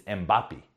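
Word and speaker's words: Mbappé: So look, if you don't know Kylian Mbappe, and I had to look up to properly pronounce his name. It's Mbappe.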